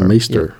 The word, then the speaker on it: Meister: I'm probably saying it wrong because I think it's a German word, and you know, It's M-E-I-S-T-E-R, isn't it? – M-E-I-S-T-R. – Meister.